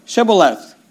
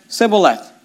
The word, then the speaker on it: Shibboleth: Then said they unto him, say now, Shibboleth. And he said, Sibboleth. For he could not frame to pronounce it right.